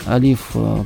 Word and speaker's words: алиф: В данном случае, если мы говорим, что вот алиф, буква алиф произносится как алиф, обозначается палочкой, то у финикийцев это был значок в виде головы быка.